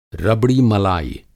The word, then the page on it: रबड़ी मलाई: Hindi